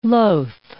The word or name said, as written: loth